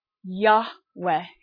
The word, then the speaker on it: Yahweh: And so what we now have is Yud-Heh-Vav-Heh in Hebrew, which is Yahweh in English. It's one way to pronounce it.